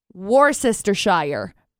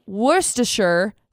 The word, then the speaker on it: Worcestershire: Worcestershire. Because there's all, I pronounced every single thing. Worcestershire sauce. Worcestershire. It's actually Worcestershire sauce.